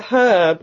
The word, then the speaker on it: herb: It was always a herb, wasn't it? – Well, of course it was. – Mm-hmm. – Do they really say herb in England? – Yes. We pronounce our H's.